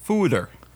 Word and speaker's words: foeder: But it's a giant oak barrel, and it's pronounced foeder. It's a Dutch foeder.